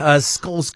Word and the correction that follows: Scholz: correctly. Can you help me with that pronunciation? – It would be Olaf Scholz.